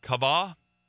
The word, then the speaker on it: kava: This one, is pronounced something like kava, and it means to bind together to be intertwined with and to expect.